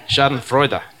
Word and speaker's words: Schadenfreude: Is that the, Schaden? Is that how you say it? I don't know Schadenfreude. Oh, you say that. Mm-hmm. Okay, right. Very German. Schadenfreude.